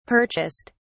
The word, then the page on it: purchased: British English